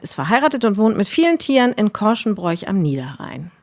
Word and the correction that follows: Korschenbroich: Genau, es spricht sich Korschenbroch. – Das Korschenbroch, ist das, ach natürlich. – Rheinische Dehnungs-I. – Korschenbroch, wie Grevenbroch.